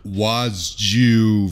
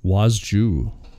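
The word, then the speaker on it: Władziu: It's actually Wasju Valentino Liberace. – Wasju. – It's like, seriously, it's not Walter. It's fucking impossible to pronounce unless you're Polish, I think. – Ah. – Wasju.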